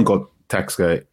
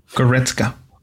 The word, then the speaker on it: Goretzka: Leon Goretzka, why have I mispronounced his name? – Goretzka. – Thank you.